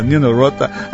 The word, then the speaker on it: Nino Rota: O Elton sempre me ensina direitinho a pronúncia Nino Rota.